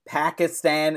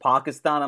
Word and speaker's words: Pakistan: It's like every time he said, like, Pakistan instead of Pakistan, I'm like, you know how to pronounce it, man.